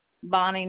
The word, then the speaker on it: Bonnie: Bonnie Newman.